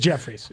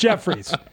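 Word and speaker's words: Jeffires: It's just pronounced Jeff Fires. – Oh, but it's spelled Jeff Fires also. – I know. That's what I mean. It's pronounced Jeffries, but it's always the joke that it was should be pronounced Jeff Fires.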